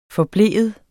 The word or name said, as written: forblive